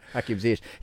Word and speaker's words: Hakim Ziyech: Hakim Ziyech.